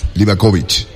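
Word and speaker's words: Livaković: Livakovic. La C en esos dialectos se pronuncia con Che.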